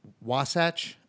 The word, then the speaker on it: Wasatch: He plays for a academy out there called Wasatch Academy, and you'll probably need to correct me again on how to pronounce that. – No, yeah, that was good. That was fine.